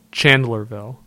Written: Chandlerville

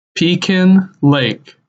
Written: Pekin Lake

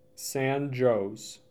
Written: San Jose

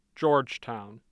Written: Georgetown

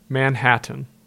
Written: Manhattan

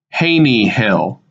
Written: Haney Hill

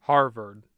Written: Harvard

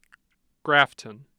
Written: Grafton

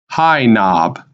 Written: High Knob

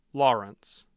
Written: Lawrence

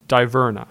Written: Divernon

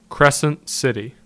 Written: Crescent City

